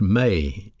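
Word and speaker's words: me (μή): Along with the word judge, we have a Greek particle pronounced me, M-E.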